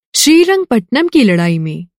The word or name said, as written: लड़ाई